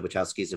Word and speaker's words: Wachowski: but without either of the Wachowskis involved. I think that's actually pronounced Wachowski. I think I've been saying it wrong.